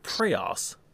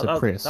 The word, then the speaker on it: Prius: Just kind of Prius. – Well, no, it's Prius.